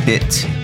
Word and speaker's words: Bit: The name Mangue Beat, B-E-A-T, comes from the song Mangue Bit, B-I-T, by Mundo Livre S.A.A., one of the two original Mangue groups with Chico Science and Nassau Zumbi. In Portuguese, bit is pronounced like beat, and journalists started to name the movement after the song.